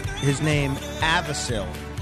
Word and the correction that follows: Avicii: That would be Avicii. – Avicii. Avicil. Avicii. So what's that L doing there?